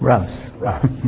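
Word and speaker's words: Rouse: Roger is the Ruth, is that how you pronounce it? – Russ.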